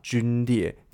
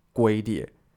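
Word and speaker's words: 龟裂: 那美国人其实他们自己也都会错，就有点像中文，有些人会不小心把军列念成龟列。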